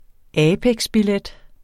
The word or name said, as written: apex-billet